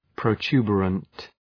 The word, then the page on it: protuberant: prəʋ’tu:bərənt